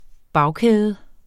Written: bagkæde

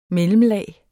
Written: mellemlag

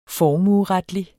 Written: formueretlig

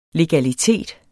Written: legalitet

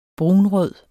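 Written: brunrød

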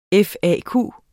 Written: FAQ

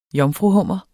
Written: jomfruhummer

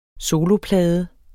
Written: soloplade

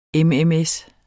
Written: mms

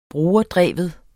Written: brugerdreven

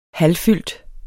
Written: halvfyldt

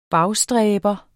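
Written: BAGSTRÆBER

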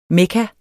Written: mekka

